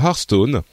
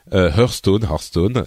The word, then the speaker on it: Hearthstone: c'est ce court-métrage sur Hearthstone, Hearthstone, les deux prononciations sont valides, qui est pour le coup un vrai court-métrage, qualité Disney Pixar machin.